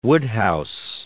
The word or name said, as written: Wodehouse